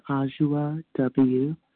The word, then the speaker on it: Adwoa: My name is Anjua W. I'm in Vermont, and it's spelled A-D-W-O-A, if you're looking for me on the member list.